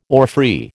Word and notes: orphrey: American English

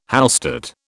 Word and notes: Hallstatt: British English

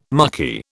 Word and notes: mucky: British English

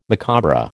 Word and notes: macabre: American English

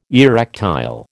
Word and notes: erectile: American English

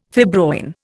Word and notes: fibroin: Indian

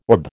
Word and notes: orb: American English

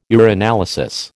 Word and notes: urinalysis: American English

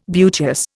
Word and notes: beauteous: Indian

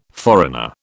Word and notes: foreigner: British English

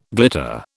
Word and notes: glitter: British English

